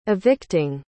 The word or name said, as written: evicting